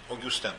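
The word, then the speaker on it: Augustin: Огюстен, Августин, Августин. Огюстен по-французски произносится.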